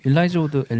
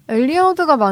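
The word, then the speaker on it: Elijah Wood: 근데 일라이자 우드, 엘리야 우드 어느 쪽이 맞는 발음인가요? – 엘리야 우드? – 엘리야 우드가 맞는 발음이라고 알고 있어요.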